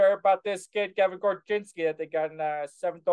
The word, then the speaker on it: Korchinski: Another signing that happened: first round pick, 7th overall, Kevin Korchinski. – That's right. – Did I say that right? – Korchinski. Yeah. Korchinski. That's a great Chicago name.